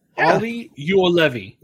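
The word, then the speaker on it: Olli Juolevi: Ryan, what is the correct pronunciation? – Bitch, I just looked at it. – And that's why he's the hockey expert. It's Ollie Juolevi.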